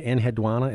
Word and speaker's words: Enheduanna: There's a name you bring up in here, Enheduanna. Is that, am I pronouncing it correctly, from Mesopotamia? – That's right.